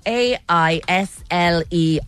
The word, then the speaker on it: aisle: The word that you have to rhyme with is aisle. A I S L E. Aisle.